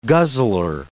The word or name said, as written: guzzler